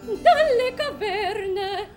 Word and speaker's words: caverne: Dalle caverne. – Because the V, you're going to the stressed syllable of caverne, and you're doing it by doubling the V. – Okay. – Da. So it's dalle caverne.